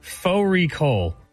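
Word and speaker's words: Fårikål: Faux recall, which is a mutton and cabbage. It's spelt like far recall.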